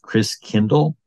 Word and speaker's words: Christkindl: And the German pronunciation of Christ child is Christkindl, like kindercare, kindergarten. Kind means child. Christ means Christ. And so over the centuries, Christkindl got pronounced Kris Kringle. So Christkindl is really Christkindl, which means Christ child.